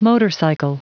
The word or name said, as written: motorcycle